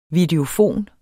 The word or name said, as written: videofon